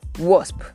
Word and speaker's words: wasp: Wasp, that insect is a wasp, not a wasp.